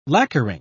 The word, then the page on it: lacquering: British English